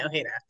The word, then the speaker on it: Ojeda: A lot of people will think it's Japanese because they think it's Ojeda. – Oh yeah, I could see that from so, how it's spelled. Okay. Yes. Okay. – So the J is silent.